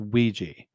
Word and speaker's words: Ouija: Because it's pronounced Ouija.